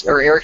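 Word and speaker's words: Erik: If you just type in Eric Stuckey, Eric Stuckey Music is what it is, and a lot of people they can't find me because they spell my first name wrong.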